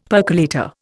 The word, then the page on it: percolator: Indian